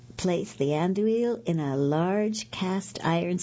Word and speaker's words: Andouille: Oh, and 2 tablespoons of sriracha chili sauce, 2 12-ounce packages of Andalee sausage links. I did not pronounce that right. Andouille, I guess it is sausage links.